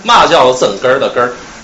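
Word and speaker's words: 嘛叫真哏儿的哏儿: 对，就我就要说这个啊，这题目叫，也得用天津话读，叫嘛叫增根儿的根儿，嘛叫增根儿的根。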